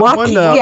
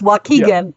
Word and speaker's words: Waukegan: Somebody's going to say, it's pronounced like this. So it's that Waukegan. – One. – It's Waukegan.